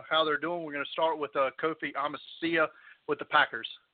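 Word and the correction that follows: Amichia: Kofi, he's it's Amici is how you pronounce it, and he's actually gearing up for training.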